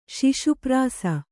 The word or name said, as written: ಶಿಶುಪ್ರಾಸ